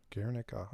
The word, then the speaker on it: Guernica: and so, you know, when you guys popped up on, is it Guernica or how do you pronounce it? – I think at 4AD, I think they called it Guernica. – Guernica.